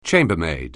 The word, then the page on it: chambermaid: anglais